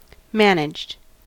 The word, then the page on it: managed: American English